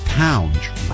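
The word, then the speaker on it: Tounge: That's Townge, rhymes with lounge.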